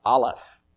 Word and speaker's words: Aleph: The first thing that you might see is a word that's pronounced Aleph. A-L-E-P-H.